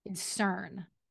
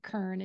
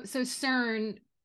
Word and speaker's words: CERN: So then the next theory is CERN, that CERN is responsible. And I remember the first time we talked about this, I pronounced it Kern, and you were like, Kern?